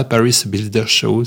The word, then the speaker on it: Paris Builders Show: Je prononce comme ça, Paris Builders Show.